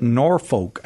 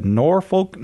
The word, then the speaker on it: Norfolk: You say it Norfolk, I think, if you're in Nebraska. Norfolk, Nebraska, watching on YouTube.